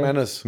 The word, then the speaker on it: McManus: McManus, wird das ausgesprochen? McManus. McManus. Das weiß ja sogar ich.